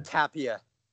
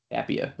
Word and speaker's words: Tapia: The only Rockies player I have is it Tapia? Is that how you pronounce it? Yeah. – Tapia. – Tapia.